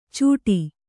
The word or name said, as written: ಚುಟಿ